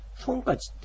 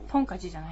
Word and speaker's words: ソンカチ: でこの正月のことをですね、これアイヌ語というか、まあ、日本語がアイヌ語の元に入ったようですが、ソンカチっていうんですね。 – ソンカチ。 – ソンカチ。正月というのがですね、発音が変わってソンカチとか。 – ポンカチじゃないんだ。 – ソンカチじゃないね。ソンカチ。